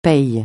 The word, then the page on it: paye: francais